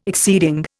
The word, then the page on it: exceeding: Indian